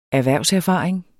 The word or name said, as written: erhvervserfaring